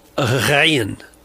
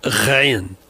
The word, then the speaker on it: ghayn: It's usually transliterated as G-H, and it's called Ghayn. Ghayn.